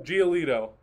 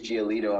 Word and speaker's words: Giolito: There was Mills and Lucas Giolito, if I'm pronouncing that correctly, from the White Giolito. Sox. What was it? – Giolito, thank you. Lucas Giolito of the White Sox, he had the other no-hitter.